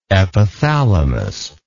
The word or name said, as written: epithalamus